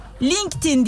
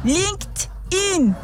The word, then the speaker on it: LinkedIn: Şimdi artık arkadaşlar LinkedIn demiyorum. LinkedIn diyorum. Yeni düzeltmem geldi. LinkedIn diye okunur. İngilizce yaklaşık. LinkedIn. D sesi çoğu zaman T'ye yaklaşarak. Ti.